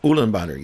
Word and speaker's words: Ulaanbaatar: Ulaanbaatar. – Yes. – That is the capital. – Yes. – And I think I said it right. – Ulaanbaatar, yes.